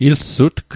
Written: ilsutg

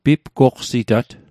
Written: pipqoqsitat

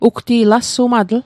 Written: alsumatl

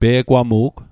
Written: pe'gwamu'g

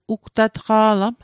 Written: ugtatqa'lam

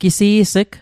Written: gisi's'g

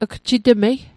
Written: gjitmei

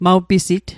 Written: maw pisit

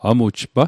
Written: amujpa

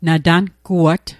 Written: natanguat

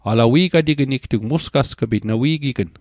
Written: musgasg'pit

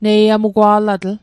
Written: neiamugwa'latl

